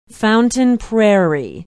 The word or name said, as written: Fountain Prairie